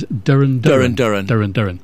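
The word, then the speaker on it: Duran Duran: Rio by, I think the correct pronunciation, according to Tony Blackburn, yes, is Durin Durin.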